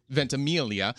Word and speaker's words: Ventimiglia: How do you say his name? – Ventimiglia? – Ventimiglia? – Ventimiglia. – Ventimiglia. – Right.